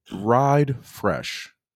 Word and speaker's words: Ride Frsh: So real quickly, in Blessed Report, we're going to shout out or bless up Ride Fresh. And that's spelled R-I-D-E-F-R-S-H. So got rid of the E.